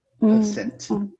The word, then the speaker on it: percent: A ênfase, na verdade, está uma sílaba para frente. 100%. Percent.